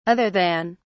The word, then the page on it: other than: British English